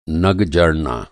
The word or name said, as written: नग